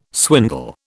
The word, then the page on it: swindle: British English